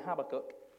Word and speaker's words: Habakkuk: Now, there's several ways, depending on what country you, you come from. This depends on how you're going to pronounce this this morning, but we, in our, my humble abode, we call it Habakkuk.